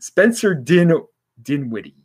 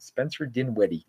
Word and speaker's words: Dinwiddie: Spencer Dinwiddie. Yeah, that's how you pronounce it. Spencer Dinwiddie.